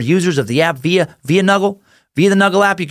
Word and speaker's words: Nugl: Find the best customer service, best weed-related products on Nuggle. N-U-G-L. That's how it's spelled. N-U-G-L. Nuggle.